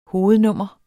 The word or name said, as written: hovednummer